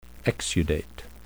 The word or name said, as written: exudate